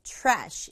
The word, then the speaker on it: trash: 글자는 다섯 개지만 박자는 한 박자로 trash 이렇게 발음해요. Bottle.